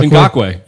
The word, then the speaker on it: Ngakoue: Yannick Ngakoue, is that how you say it, from Jacksonville? – Ngakoue.